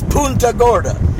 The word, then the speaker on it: Punta Gorda: It's Punta, I cringe when I hear it. – Yeah, it's Punta Gorda. – Yes. – That's the way it's pronounced. – You might as well spell it P-O-O-N-T-A.